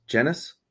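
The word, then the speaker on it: Genuis: Garnet Genius. Genis. I always say it right. It's Garnet Genis.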